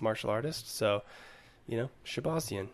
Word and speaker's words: Shahbazyan: Derek Brunson versus Edmen, I'm gonna butcher this last name, Shahbazyan? – That sounds good. Shahbazyan. I think we can agree to that.